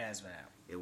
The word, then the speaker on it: ASVAB: ASVAB? – ASVAB.